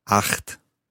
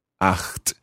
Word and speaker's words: acht: Acht. Acht. Now that's that ch sound, which for a Scot is quite easy to say.